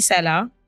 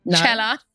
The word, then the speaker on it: Cela: Well, I'm so excited to have my guest on, Krissy Cela. – Cela. – What I knew I was gonna say that, and it even says pronounced Cela, like Coachella.